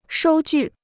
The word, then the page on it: 收据: shōu jù